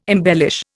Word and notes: embellish: Indian

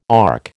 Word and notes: ARC: American English

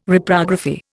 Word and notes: Reprography: Indian

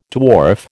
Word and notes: dwarf: American English

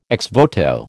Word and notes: ex voto: American English

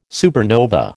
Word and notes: supernova: American English

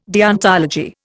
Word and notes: deontology: Indian

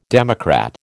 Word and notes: democrat: American English

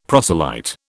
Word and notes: proselyte: British English

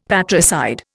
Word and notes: patricide: Indian

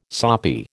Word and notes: soppy: American English

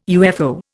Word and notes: UFO: Indian

